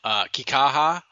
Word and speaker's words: Kikaha: I like, and I'm going to butcher his name, even though Andrew told me like 6 times in the pregame, Kikaha.